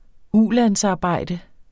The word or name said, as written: ulandsarbejde